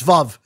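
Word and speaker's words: Veuve: No, it's Vuv. – Is it Vuv? She said it wrong. Nope. The woman from the company said it's Vuv like love.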